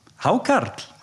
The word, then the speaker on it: Hákarl: Do you pronounce it Hákarl? – Hákarl. – Yeah. Hákarl. – Yes.